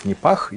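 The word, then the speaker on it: Nipah: Ну, это вирус Непах, если быть точнее, его так произносят.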